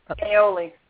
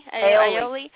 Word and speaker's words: aioli: Aioli. Aioli. – Aioli.